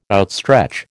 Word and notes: outstretch: American English

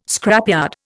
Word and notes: scrapyard: Indian

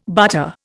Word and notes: butter: Indian

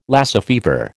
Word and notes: Lassa fever: American English

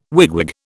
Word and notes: wigwag: British English

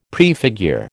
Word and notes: prefigure: American English

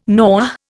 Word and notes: Noh: Indian

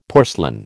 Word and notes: porcelain: American English

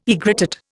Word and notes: aegrotat: Indian

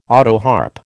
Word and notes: autoharp: American English

